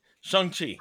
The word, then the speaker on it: Shang-Chi: Shang Chi.